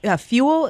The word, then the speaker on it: Fewell: So one of the things that strikes me, you had this quote from Brent Fuel. Is that how you pronounce his last name? Fewell? – Yes. – Or is it fuel, like gasoline? – Fuel.